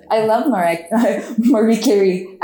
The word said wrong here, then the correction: Mariah Carey: I think it's pronounced Mariah Carey.